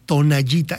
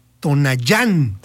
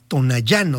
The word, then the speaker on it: Tonayán: Estoy viendo, porque fíjate que yo siempre escuché tonallita, el tonallita. No, es que hay un tonallita. Pero realmente el nombre es Tonayán, con acento en la A. Tonayán. O sea, ni siquiera es tonalla, es Tonayán.